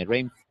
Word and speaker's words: Reims: El, el el equipo de Francia donde jugaron, este, el Reims, perdón, con M, Reims,